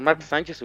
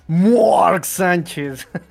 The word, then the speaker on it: Mark Sánchez: Este roster con Mark Sánchez hubiera sido campeón. pero pronúncialo bien. Mark Sánchez.